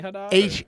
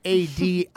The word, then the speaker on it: Hadid: Is she related to like the Tammy Haddad? – Hadid.